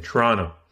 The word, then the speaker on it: Toronto: Without the T at the end. Toronto. – No T at the end. – Yeah, that's how you say it. It's so funny. A silent T.